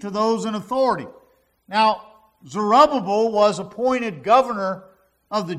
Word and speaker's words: Zerubbabel: So I've pronounced it two different ways. Zerubbabel, Zerubbabel. Which one is it, Josh? Zerubbabel. That's easy enough.